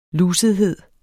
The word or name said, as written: lusethed